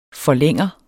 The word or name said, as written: forlænger